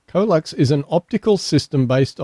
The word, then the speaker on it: CoeLux: I'm saying Colux. – Yep. – COELUX. – Yep.